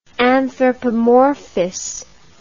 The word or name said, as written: anthropomorphously